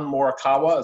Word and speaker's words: Morikawa: And I think his name is Colin Morikawa.